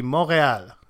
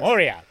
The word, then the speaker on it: Montréal: Yeah, Athletic, it would be Montréal. The T Montréal. Is silent, so the T is silent in French. Yes. – Montréal.